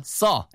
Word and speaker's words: saw: 뭔가를 보다 saw. S-A-W. 전부 다 발음이 똑같아요. thought. 그다음에 saw 이런 식으로 할 때 아 발음이에요. 아 발음.